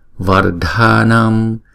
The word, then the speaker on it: vardhanam: Su siddhi kari su siddhi kari vardhanam vardhanam. Chữ DH này là D. Chữ A này là hai nhịp. Chữ A này là hai nhịp luôn.